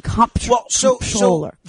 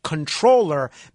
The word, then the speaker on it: comptroller: I always thought the proper pronunciation was controller, meaning as if it was spelled controller. Some people, like Dominic Carter, for instance, who's been around the block, he pronounces it full M, full P, comptroller. But what you're saying is the hybrid pronunciation of not comptroller, not controller, but comptroller.